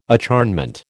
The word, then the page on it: acharnement: American English